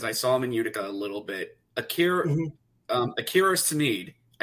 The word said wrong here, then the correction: Schmidt: I think I got his Schmidt. Name correct. – Akira Schmidt.